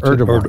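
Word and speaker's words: Erdoğan: How do you pronounce Erdogan.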